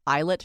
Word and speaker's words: ILIT: At your practice, you have brought many new therapies to the Columbus area, and one is called the Islet treatment. Am I saying that right? – That's right, yeah.